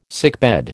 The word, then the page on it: sickbed: American English